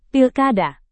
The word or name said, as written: pilkada